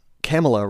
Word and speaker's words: Kamala: Anyway, she writes, Kamala rhymes with Mamala.